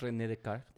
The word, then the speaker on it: Descartes: René Descartes o Descartes. Si estamos pronunciando en francés, René Descartes.